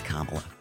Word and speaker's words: Kamala: Except, like, it's pronounced Kamala.